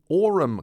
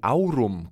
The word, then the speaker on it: Aurum: Turning now to the Aurum Quartet, or the Aurum Quartet, depending on which pronunciation you prefer for the Latin word for gold.